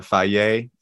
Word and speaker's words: Fage: My personal favorite coming from a Greek guy is that it's the Faye. It's like a blue thing, and it's spelled F-A-G-E, pronounced Faye.